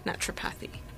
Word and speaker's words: naturopathy: Am I pronouncing that correctly, or is it naturopathy? Naturopathy. – Um, naturopath, and if you're talking about naturopathy, it's that's how it's pronounced.